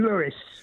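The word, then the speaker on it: Louis: But Tim, Satchmo pronounced his name Lewis.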